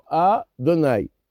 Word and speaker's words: Adonai: Donc le Shem Adanut, il a trois syllabes. Donc on doit faire un arrêt sur la première syllabe. Et les deux dernières syllabes sont lues ensemble. On va dire Adonai, Melech. Donc A, on ne doit pas mettre l'accent tonique sur le Do, sur l'avant-dernière lettre. C'est une erreur.